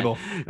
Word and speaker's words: Daboll: Daboll.